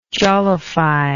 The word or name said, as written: jollify